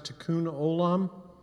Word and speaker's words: Tikkun Olam: It's called, I pronounce it, Tikkun Olam,